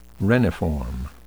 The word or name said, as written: reniform